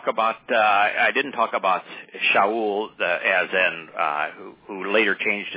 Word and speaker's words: Shaul: He says, "But, you know, I even named my youngest son, his middle name is the Hebrew pronunciation, Shaul."